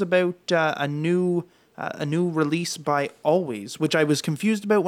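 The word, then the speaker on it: Alvvays: Always, pronounced Always, but it's spelled with two V's, is a Toronto guitar-based pop group.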